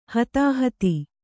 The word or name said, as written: ಹತ್ತಾಹತ್ತಿ